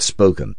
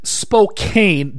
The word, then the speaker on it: Spokane: To answer your question, if you type S P O K A N E into my text field, I pronounce it spoken. At least I don't say Spokane. – Yeah, Spokane drives people here up the wall. It is Spokane.